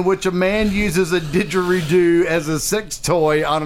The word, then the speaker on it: didgeridoo: But yet he's able to take didgeridoo. – Didgeridoo. – And he says it right the first time without any effort.